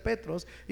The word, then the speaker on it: Petros: O en el griego es Petros, se pronuncia Petros y significa piedrecita.